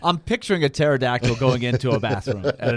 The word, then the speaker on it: pterodactyl: Why can't you hear a pterodactyl going to the bathroom? – Pterodactyl going to the bathroom. I can't fit in there. – Because the P is silent.